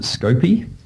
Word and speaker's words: Scoopy: Is that how I pronounce it? – Well, I like to pronounce it Scoopy. – Scoopy, right, okay.